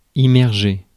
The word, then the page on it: immerger: [i.mɛʁ.ʒe]; France